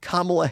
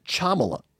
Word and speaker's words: Kamala: And then they started pronouncing Kamala Harris's name. And they pronounce it Chamala, a C-H.